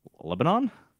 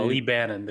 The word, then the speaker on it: Lebanon: Lebanon? – Huh? – No, Lee Bannon. – Yeah, Lee Bannon. – You pronounce it Lee Bannon, but like that isn't that just the same spelling as Lebanon? – No, huh?